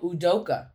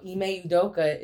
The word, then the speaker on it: Udoka: Oh, Udoka.